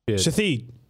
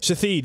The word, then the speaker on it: Shithead: Tell me how to pronounce it. – Okay. – S-H-I-T-H-E-A-D. – Shatheed. – You got it. – Shatheed. Because yes. I ain't no way in hell they're trying to say shithead.